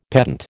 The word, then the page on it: pedant: American English